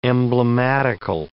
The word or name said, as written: emblematical